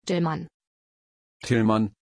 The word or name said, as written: Tilman